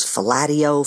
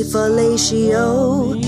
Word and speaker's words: fellatio: And I recall hearing in my head the word fellatio as rhyming with patio. So it was fellatio, fellatio. This word was constantly being brought up, fellatio. It wasn't until the movie Hair, when you hear the song Sodomy, fellatio.